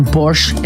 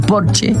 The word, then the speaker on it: Porsche: O decir que tengo un Porsche en lugar de decir tengo un Porsche, porque en alemán Porsche se dice Porsche porque todas las S al final se pronuncian, como Marlene Dietrich, por ejemplo, que todo el mundo dice Marlene, pero es Marlene.